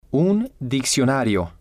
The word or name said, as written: un diccionario